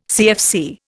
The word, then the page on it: CFC: Indian